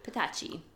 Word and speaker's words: Petacci: That's how you say it, right? Petacci? – Petacci.